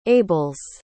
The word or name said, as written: ables